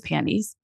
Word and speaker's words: panties: It's panties. But if you worked at Aerie, it was undies. – Oh. – The T is silent. Panties. – Panties. – Panties. Like pansies.